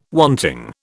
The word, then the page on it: wanting: British English